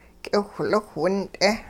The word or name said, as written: k'iwhliwh wint'e